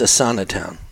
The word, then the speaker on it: Asana: Mercs in Azintown are only a portion of, or maybe it's Asana Town. I think it's Asana Town. Asana Town. – How do you spell it? – A-S-A-N-A Town.